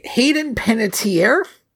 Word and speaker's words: Hayden Panettiere: Hayden Panettiere? – Yes.